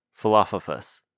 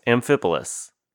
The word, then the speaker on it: Amphipolis: Philophophus. Philopotus? – Snuffleupagus? – Yes. Amphipolis,